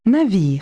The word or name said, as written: ship